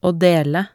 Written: å dele

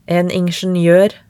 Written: en ingenioer

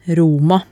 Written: Roma

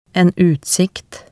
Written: en utsikt

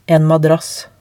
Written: en madrass

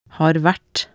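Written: har vært

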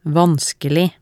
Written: vanskelig

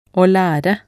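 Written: å lære

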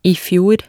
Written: i fjor

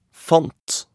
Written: fant

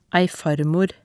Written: ei farmor

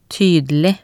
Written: tydelig

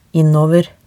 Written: innover

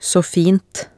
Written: saa fint

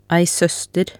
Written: ei soester

